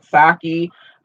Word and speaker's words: Psaki: But anyway, Jen, I'm just assuming that the P is silent. Psaki,